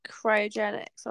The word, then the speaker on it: cryogenics: And this, if you Google, like, cryogenics, or how did you say it again? – I think it was cryogenics. I'll have to cryogenics, check. That's it, yeah.